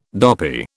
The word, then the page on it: dopy: British English